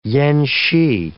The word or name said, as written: yen-shee